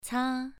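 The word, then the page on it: ca: tone 2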